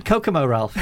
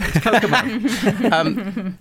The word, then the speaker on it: Kokomo: How do you pronounce it? – Kokomo, Ralph. It's Kokomo.